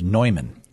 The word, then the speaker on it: Neumann: But some people pronounce America's John Newman as Neumann.